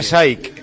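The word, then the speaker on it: Shayk: Irina Saik. – Yo pensé que era una actriz japonesa. – Yo pensaba que se pronunciaba Saki.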